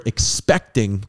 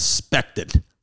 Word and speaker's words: expecting: The network allowed the pregnancy storyline, but insisted the word expecting be used instead of pregnant. And Arnaz actually got laughs when he deliberately mispronounced it as spected. He said spected.